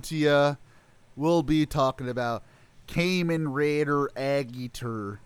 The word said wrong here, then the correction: Agito: No, you said it wrong. It's Agito.